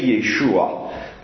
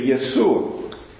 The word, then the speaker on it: Yeshua: We know that in Galilee there were Greek speakers that would have not pronounced his name correctly, because Greek speakers can't say Yeshua. They would say Yesu.